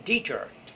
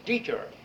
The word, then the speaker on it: Detur: D-E-T-U-R, the Deter Prize. – Deter. – Deter, all right. – You didn't have Latin. You didn't know how to pronounce it. Deter.